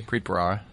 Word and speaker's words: Preet Bharara: Preet Bharara.